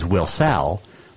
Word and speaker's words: Wilsall: Wilsall was actually, it's W-I-L-S-A-L-L. Wilsall, or also known as Wilsall, was named after the town's first couple, Will and Sally. That is how Wilsall got its name. And a correct pronunciation is Wilsall.